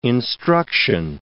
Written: instruction

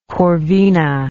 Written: corvina

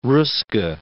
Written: Ruska

